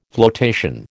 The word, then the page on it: flotation: American English